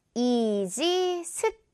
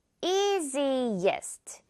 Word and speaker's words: easiest: 자, 이럴 때 easiest 이렇게 발음하면 안 된다는 겁니다. easiest가 아니라 easiest. easiest라고 생각을 하면 돼요. easy 다음에 이가 하나 더 들어가는 거예요, 소리가.